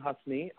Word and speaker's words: Husney: Yeah, you can find me personally at Evan Husney on Twitter and Instagram.